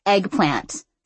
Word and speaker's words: eggplant: Eggplant, my mom said, except she pronounced it eggplan. – The woman frowned a little. – You know, it's eggplant, said the woman after my mom put the camera down. – What? – Eggplant, the woman said, emphasizing the T so vigorously she was practically spitting on my mom.